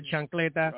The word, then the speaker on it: chancleta: The how do you say it? Chicleta? – The chancleta. The chancleta.